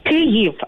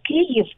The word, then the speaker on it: Kyiv: Kyiv. Uh, the correct pronunciation is Kyiv. It's like K Y I V. Kyiv.